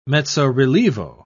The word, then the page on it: mezzo-relievo: British English